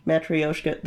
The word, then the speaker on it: Matryoshka: Matryoshka, the nesting doll. – Oh, Russian nesting, okay. – Yeah, I totally mispronounced that, but I'm gonna blame Stir Crazy, I don't know. – Did very good job pronouncing the Russian word.